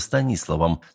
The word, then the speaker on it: Станиславом: Ну а кто еще, идеально говоря по-русски, станет называться Станиславом с ударением на втором слоге, а не на третьем? – И тут меня как током ударило.